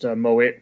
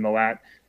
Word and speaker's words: Mowat: Yes, Andy Mowat. It's pronounced Mowat rather than Mowat.